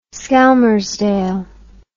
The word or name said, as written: skelmersdale